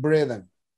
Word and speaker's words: Braden: El doctor eh, er, eh, Joe Dispensa cuando da conferencias trabaja mucho, no sé si han escuchado, con Greg Bredan, bueno, se, se escribe Braden y se pronuncia eh, Greg Bredan.